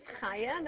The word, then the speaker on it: Chaya: Okay, so my name is Chaya. That's spelled C-H-A-Y-A.